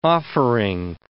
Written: offering

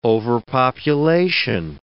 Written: overpopulation

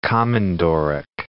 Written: komondorok

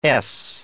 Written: ess

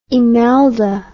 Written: emelda

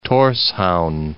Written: Torshavn

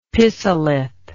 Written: pisolith

